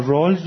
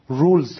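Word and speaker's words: Rawls: یا رالز هم همینطور، بعضی اصلا به تفاوت لهجه برمیگرده، مثلا رالز رو انگلیسی بریتیش، کسی که لهجه بریتیش دارن بریتانیایی رولز تلفظ میکنن رولز،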